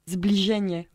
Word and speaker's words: Zbliżenie: Ça se prononce Zbliżenie.